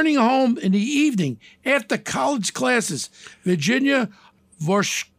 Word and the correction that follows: Voskerichian: I can't pronounce it, Mike. – Voskerichian.